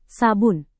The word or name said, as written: sabun